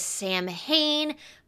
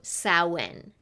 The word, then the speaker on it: Samhain: And for many who have never heard the word Samhain pronounced, it is read as if it says Samhain, but it is accurately pronounced sow, as in like the cow, and when. So Samhain, not Samhain.